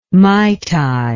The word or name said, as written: mai tai